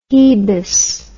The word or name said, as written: hebess